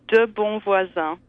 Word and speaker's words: de Bonvoisin: The French pronunciation is De Bonvoisin, which, if you translate it, actually means good neighbor.